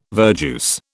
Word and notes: verjuice: British English

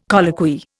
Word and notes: colloquy: Indian

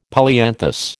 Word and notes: polyanthus: American English

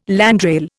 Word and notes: landrail: Indian

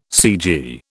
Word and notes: cg: British English

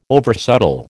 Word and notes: oversubtle: American English

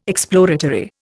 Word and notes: exploratory: Indian

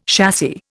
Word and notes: chassis: Indian

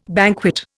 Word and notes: banquet: Indian

